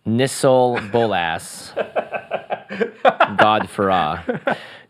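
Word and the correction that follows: Nicol Bolas: Nickel Bolas, God Pharaoh.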